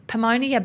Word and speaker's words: pneumonia: Or as one of my teammates said, is pneumonia bad? – Oh. – And I was like, oh, George, that's not – first up, the silent P.